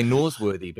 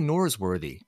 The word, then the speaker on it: Norsworthy: Well, it depends on how you treat that letter R, right? Because if you're an American, you really know you need to put that R in there, right? R. Because I would say Norsworthy, but you can say Norsworthy. Yeah, it's not butchering it. It's how it's said on the other side of the world.